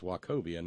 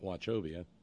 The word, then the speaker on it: Wachovia: Apparently it's Wachovia, not Wachovia.